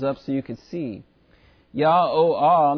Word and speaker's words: YHWH: And then the last H, again, Hey, H-E-Y, is again pronounced Ah. So if you look at the phonetic way to pronounce YHWH, it's Yah-O-Ah. We would say Yahweh in our English, but they in the Hebrew would say Yah-O-Ah.